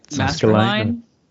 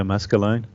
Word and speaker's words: Maskelyne: What Maskelyne, or how do you say his name? Do you know? – Maskelyne. – Yeah, Maskelyne. Nigel Maskelyne.